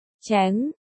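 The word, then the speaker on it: chéng: Thành và Thừa đều đọc là, chén, ánh mắt Cảnh Nhất Thành sáng ngời.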